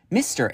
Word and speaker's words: MISTR: I recently stumbled upon this organization called MISTR. It's spelled M-I-S-T-R. Like you just get rid of the E.